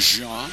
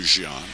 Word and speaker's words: Jean: I remember going down and talking to him a couple of years ago in spring training the first time we saw him, not knowing how to pronounce his last name, not knowing if it would be Jean, the French pronunciation, as in the former player Domingo Jean. But he said, no, I'm not from Haiti, I'm not from the Dominican, I'm from Florida, and it's Jean, J-E-A-N, just like a pair of jeans.